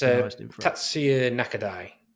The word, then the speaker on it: Tatsuya Nakadai: That's yeah, it's Tatsuya Nakadai, I believe, is how you pronounce it.